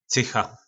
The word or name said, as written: cycha